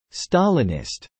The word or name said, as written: Stalinist